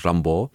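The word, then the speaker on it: Rimbaud: Tohle vlastně řekl i ten Artur, Artur Rambo, se to má prý vyslovovat, Artur Rambo,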